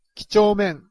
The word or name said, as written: kichomen